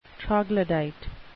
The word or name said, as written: troglodyte